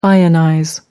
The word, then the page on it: ionize: American English